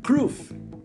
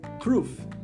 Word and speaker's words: crwth: O nome do instrumento é Kruf. Kruf. Como eu disse, eu não sei exatamente, exatamente como pronuncia, mas se escreve C-R-W-T-H cuja pronúncia eu creio que se aproxima a Kruf.